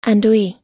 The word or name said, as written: andouille